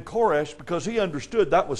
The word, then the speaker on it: Koresh: Now, it's interesting to note that Cyrus's name in Hebrew is pronounced Koresh.